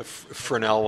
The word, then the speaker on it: Fresnel: A Fresnel lens.